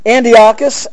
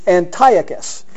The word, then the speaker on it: Antiochus: Now, where I come from, back east, I don't know, where I grew up, everybody pronounced it Antiochus. And I think that's acceptable because I've heard other individuals talk about that. But I've also come to the realization that there's quite a few people that pronounce it Antiochus. Antiochus as opposed to Antiochus.